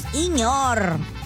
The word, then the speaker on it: señor: Dice, hola, buenas tardes, me gusta cómo se dice, señor. Soy de San Luis Potosí, radico en Matamoros. Dice, y para San Luis Potosí, Monterrey, lo pronunciamos Iñor. Me dice, trata de pronunciarlo así, le gustaría a todos los radioescuchos.